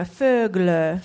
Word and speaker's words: Fögle: 페글레. – 페글레. – 페글레. 이거 다 한국어에 없는 발음들이거든요. 네. 이거 스웨덴어 이름이 Fögle.